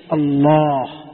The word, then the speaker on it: الله: أنا عندما نكتب الله هل نكتب ألف قبلها؟ تبعي ولا لا؟ الألف هذه هل نكتبها؟ لكن تنطق ولا ما تنطق؟ تنطق، ولهذا جاء في المصحف إشارة صغيرة إليها كذا.